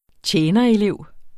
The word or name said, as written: tjenerelev